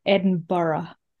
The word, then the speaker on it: Edinburgh: The real pronunciation, if you want to pretend that you're kind of local, is Edinburgh.